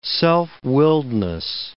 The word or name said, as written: self-willedness